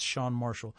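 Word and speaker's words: Chan Marshall: And it's spelled C-H-A-N, like Chan, but it's Shan Marshall.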